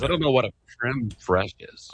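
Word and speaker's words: crème fraîche: He says it's called creme fraiche and then empanada. For some reason, I kept saying empanada or something like that.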